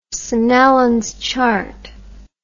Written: Snellen's chart

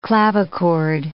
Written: clavichord

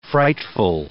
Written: frightful

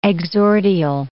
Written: exordial

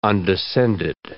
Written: undescended

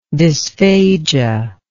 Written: dysphagic

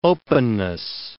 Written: openness

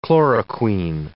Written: chloroquine

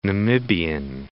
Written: Namibian

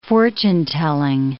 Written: fortune-telling

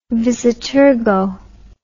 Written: vis a tergo

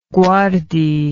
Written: guardi

